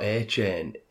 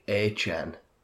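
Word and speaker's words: Achane: Feel free to check the tape for the receipts, but I do believe Devon A-Chain, or how is he saying it's pronounced? – I don't know. – It's not A-Chain, it's like A-Chan.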